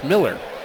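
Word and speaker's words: Mueller: That's M-U-E-L-L-E-R, but it's pronounced Miller.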